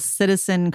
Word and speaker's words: CTZN: So the one we're going to close out on is called Citizen Cosmetics. It's spelled C-T-Z-N.